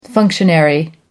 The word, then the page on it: functionary: American English